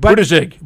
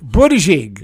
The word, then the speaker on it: Buttigieg: I don't know how to pronounce it. Buttigieg. – Buttigieg. – Buttigieg. – You had to know it wasn't Butt. – I had to know. – You had to try to figure out how to not have the butt.